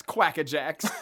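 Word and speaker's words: Quakka Jaxx: Pronounced or spelled Quakka space Jaxx, Quackajax.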